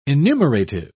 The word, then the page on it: enumerative: British English